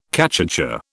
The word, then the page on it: cachucha: British English